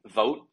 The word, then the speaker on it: Vogt: The hot rumor was, I believe, it's Stephen Vogt, I think is how you pronounce his last name. It's V-O-G-T.